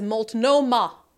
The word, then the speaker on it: Multnomah: On Portland, Bob Larson says, Multnomah. You had it right the first time. Okay. Just as it's spelled.